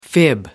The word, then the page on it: fib: American English